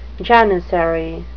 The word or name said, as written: janissary